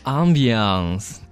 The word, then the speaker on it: ambiance: So, one of the number one things I always look for in a coffee shop is the environment or the ambiance, remember? – Ambiance 这个词语发音有点像法语的单词 ambiance。 – Yeah, it's a little French.